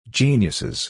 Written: geniuses

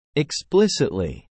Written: explicitly

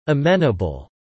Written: amenable

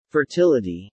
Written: fertility

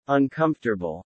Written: uncomfortable

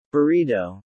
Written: burrito

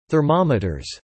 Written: thermometers

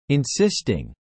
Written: insisting

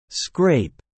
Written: Scrape

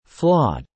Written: flawed

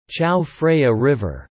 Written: Chao Phraya River

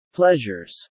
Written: pleasures

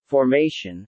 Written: formation

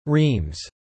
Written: reams

